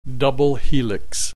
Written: double helix

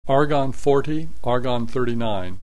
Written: argon 40 argon 39

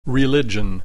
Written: religion

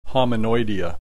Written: Hominoidea

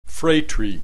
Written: phratry

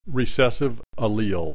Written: recessive allele

